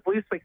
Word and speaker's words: Vlooswyk: When I won my first national title, the media has struggled saying Lisa Vlooswyk, spelling and pronouncing my name.